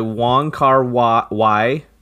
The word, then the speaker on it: Wong Kar-wai: This is In the Mood for Love, directed by Wong Kar-wai.